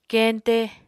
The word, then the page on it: keñte: Apatani